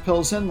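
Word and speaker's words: Plzeň: So in this case, the city of Pilsen, which is spelled P-L-Z-E-N, at least in the English language. And it is pronounced Pilsen like Pilsener.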